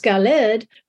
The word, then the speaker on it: Gilead: And if you remember, when we looked at Mount Gilead before, we saw that there was a parallel there with Mount Sinai, because Gilead, it can be pronounced Galed, which